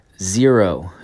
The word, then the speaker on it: Xero: Zero. It is a sneaker brand or shoe brand or footwear brand. X-E-R-O, pronounced zero.